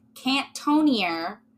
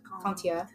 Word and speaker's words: Cantonnier: Con- I want to say okay, I'm going to pronounce it the very butchery American way Cantonier, but it's spelled C A N T O N N I E R, which. Contia. Yeah, like anything with ER.